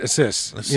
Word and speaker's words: Asus: Yeah, Asus, and you pronounce it Asus. You know, which is Pegasus.